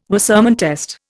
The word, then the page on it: Wassermann test: Indian